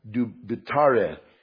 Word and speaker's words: dubitare: The Latin word for doubt is, and I asked my wife how to pronounce it because she speaks Latin. She's way smarter than me. And it's dubitare.